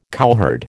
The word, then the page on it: cowherd: American English